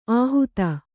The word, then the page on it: ಆಹೂತ: kannada; āhūta